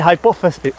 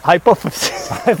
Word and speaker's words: hypothesis: So you can say hypothesis. – Hypothesis. hypothesis. – Hypothesis. – Hypothesis. Oh, and now you need to place the stress in the on the right syllable as well.